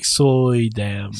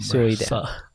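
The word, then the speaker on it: Suydam: It's like Soydam. – Soydam.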